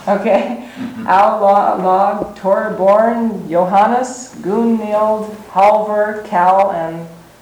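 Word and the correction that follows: Torbjørn: That'd be Torbjorn. – Torbjorn, don't pronounce the B. – Is it J there? – Yeah.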